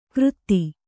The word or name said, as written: ಕೃತ್ತಿ